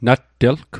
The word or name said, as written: nattelg